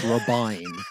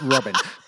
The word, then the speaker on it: Robyn: We have, when we cover Sister Wives, we're very clear, though, that it's Robyn, not Robin, because it's spelled R-O-B-Y-N, and that's Robyn. – Robyn. – If her name were Robin, it would be spelled R-O-B-I-N.